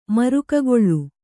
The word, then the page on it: ಮರಿಗೆಕಲ್ಲು: marige kallu